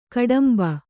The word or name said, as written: ಗೋಡುಂಬೆ